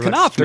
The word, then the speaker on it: Knopf: Just found out I've been mispronouncing that. – It's Knopf, man.